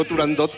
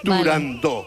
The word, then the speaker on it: Turandot: pues yo también lo digo mal y digo turandot como todo el mundo. Pero para que quede claro. – Entonces, todos tontos ya. – Para que quede claro, es turandot.